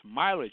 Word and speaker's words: Mila: Just saying, I'm saying her name now as Mila, whereas before I was saying Mila, because I didn't know it was pronounced Mila. I now know her name is pronounced Mila J because I happen to have seen a Facebook posting of her yesterday giving a live interview.